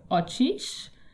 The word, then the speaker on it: Oshkosh: Oshkosh is more correctly pronounced something like Ochish, which is a shortened form of the phrase Ochkabdapesh, which means finds them and kills them.